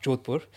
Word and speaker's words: Jodhpur: And so, as I said, we shifted from region in Gujarat to Jodhpur, pronounced Jodhpur, in case any Indians watch this podcast and think, he's gone all English on us.